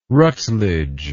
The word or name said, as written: rutledge